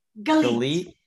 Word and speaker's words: Galit: Galit. So, Nate, my name is the best to remember that it rhymes with the word elite. So, Galit Elite.